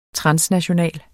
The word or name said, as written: transnational